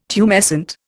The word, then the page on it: tumescent: Indian